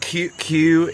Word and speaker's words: queue: But queue is the most perfect word, because it's the first one is the important letter, and then followed in a line right behind it are silent letters that don't do anything. – Oh, yeah. – So it's like a Q.